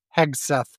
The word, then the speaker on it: Hegseth: And Hegseth, how do you say his name? – Hegseth.